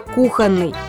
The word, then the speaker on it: кухонный: Здесь ударение на «о» – грубейшая ошибка даже в разговорной речи. Вне зависимости от предмета будет исключительно кухонный.